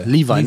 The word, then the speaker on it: Levi: Äh, ich, wie wird er ausgesprochen? Levy oder Levi Stubbs? Der Sänger ich von den Levi. Levi, Levi, ne?